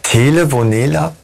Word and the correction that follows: Telenovela: Telenovela für Arme.